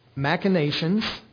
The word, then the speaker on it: machinations: against the machinations." And machinations is, it looks like machine and nations, and I even had to look up the pronunciation, so I pronounced it right.